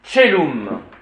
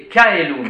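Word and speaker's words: caelum: Tchelum. – Tchelum. – On prononce le C, T-C-H. Tch. Tch. Tchelum. Un T très discret. Tchelum. Tandis que ces messieurs de la prononciation restituée vous donnent Kaelum.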